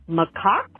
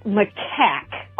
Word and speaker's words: macaque: Just in response to one of your guys' favorite pronunciations for a monkey, normally known as by you all, macaque. After 12 years of working in various animal industries and zoos, as fun as it is to say it that way, it's typically pronounced macaque.